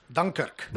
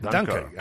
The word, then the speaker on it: Dunkirk: Hay que decir, ¿cómo se dice Dunkerque en inglés? – Dunkirk. – Dunkirk.